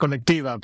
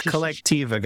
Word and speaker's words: Collectiva: I like to pronounce it Collectiva, but I think that's really cool too. – It's Collectiva, guys. I did it wrong, but I'm not going to edit that.